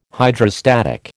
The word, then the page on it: Hydrostatic: American English